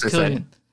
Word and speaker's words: Cillian: Found it's pronounced Cillian. – I said Cillian.